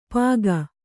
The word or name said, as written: ಪಾಗ